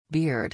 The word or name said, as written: beard